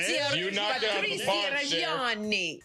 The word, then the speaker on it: Patrizia Reggiani: I love this because Gucci is a dark film about Patrizia Reggiani. Did I say that right? In the Italian accent? – You did. Patrizia Reggiani.